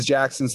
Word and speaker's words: Jacson: And so people don't see the K and they call me Jason, or they hear my name is Jackson, so they put a K in it.